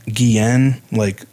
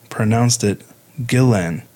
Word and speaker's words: Guillén: I've heard the name pronounced Guillén, like, you know, Spanish with the two L's, but Vanessa's sister, Myra, pronounced it Guillén.